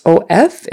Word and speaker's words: of: Even though it's O-F, it's pronounced like short U, V, of, kind of.